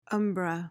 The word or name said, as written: umbra